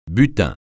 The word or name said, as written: butin